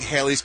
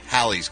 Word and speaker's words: Halley: Haley Joy because she said she's just like Haley's Comet. And she pictures her sailing through the sky, which is adorable and hilarious because it's pronounced Halley's Comet.